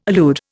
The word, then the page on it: allude: Indian